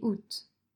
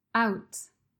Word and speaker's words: août: Dans cet épisode, je vais parler des fêtes qui ont lieu l'été, pendant les mois de juin, juillet et août. D'ailleurs, pour la prononciation, je voudrais préciser que les mois août peut être prononcé sans le A, comme je le fais ou avec le A. Out.